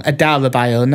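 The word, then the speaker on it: Adarabioyo: Ahmed Agassi, Tosin Adebayo, who... No, I said that wrong. Adarabayo, not Adebayo.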